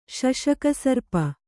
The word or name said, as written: ಶಶಕಸರ್ಪ